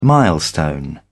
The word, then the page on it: milestone: British English